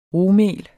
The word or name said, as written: rugmel